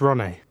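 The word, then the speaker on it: Ronay: Is it Rone or Rony? – It's Rone. – Rone. – It's a traditional Hungarian name, the normal pronunciation.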